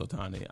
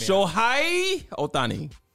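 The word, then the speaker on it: Ohtani: Pronounce it, please. – His last name is Otani. I'm not taking stabs at the first name. – Okay. – I mean, Shohei Otani.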